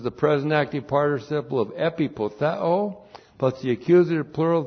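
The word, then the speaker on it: Epipotheo (ἐπιποθέω): Well, we start out with the verb epipotheo. Epipotheo looks like that in the Greek. E-P-I-P-O-T-H-E, long O. Epipotheo.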